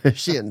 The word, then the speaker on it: Shin: My name is pronounced just like the front of your lower leg. Chin. No, shin.